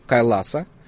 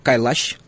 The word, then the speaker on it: Кайлаш: Шива – это одно из главных божеств индусского пантеона, которое пребывает вечно на горе Кайласа, или как Кайлаш произносят сами индусы, которая находится в Тибете.